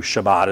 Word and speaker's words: Shabbat: Shabbat is how you say it in Hebrew. Sabbath is our word, right?